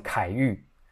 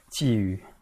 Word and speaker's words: 觊觎: 如果是我在讲，我可能会念成凯玉，不是不是哦，OK，好，觊觎，好，关于这个，这是关于禁止，right？